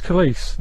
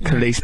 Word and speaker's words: Khalees: Right, I sort of thought that it was Khalees, but you think it's definitely pronounced Khalees. – It's Khalees. – Like police, isn't it? Khalees, police. So if that's a handy tip to remember Khalees, it rhymes with police.